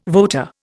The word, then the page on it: voter: Indian